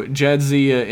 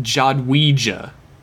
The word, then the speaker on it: Jadzia: Jadzia in English is pronounced like on the show. Jadzia in Polish is pronounced Jadwija.